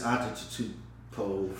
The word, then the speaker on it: Antetokounmpo: It's Antetokounmpo. From me, I hope I said that right. – We're gonna figure out how to say his name. – Antetokounmpo. – I said Antetokounmpo.